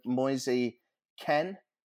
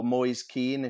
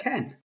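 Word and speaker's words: Moise Kean: Just to let you know, Moisey Ken, I think you've actually pronounced his name, or Moyes Keane if you're English, has just finished a penalty off and Everton are 3-0 up.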